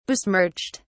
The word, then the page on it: besmirched: British English